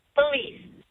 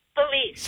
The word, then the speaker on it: Felice: Did I say your name right? – No. – How do you say it? – That's okay. Felice. – What is it? – Felice. – Felice. Okay. – F-E-L-I-C-E.